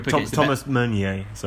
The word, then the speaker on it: Meunier: Now, if you're Thomas Meunier, to correct the pronunciation of that.